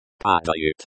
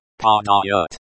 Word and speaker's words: Падают: Падают. – The verb, to fall, in the present tense, third person plural. The sound at the end of this word is like the in father. It is pronounced padayut.